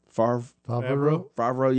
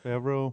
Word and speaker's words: Favreau: Favreau. – Yeah. – Favreau.